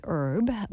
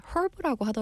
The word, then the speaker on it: herb: So actually, I believe in America, we say herb, but 영국에서는 herb라고 하더라고요. So I guess it depends on where you're going.